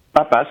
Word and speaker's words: Papas: Comment ça se prononce exactement avec tous ces S à la fin du titre de cette pièce qui est une comédie ? – Papas, vous l'avez bien dit.